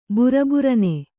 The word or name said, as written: ಬಿರಬಿರನೆ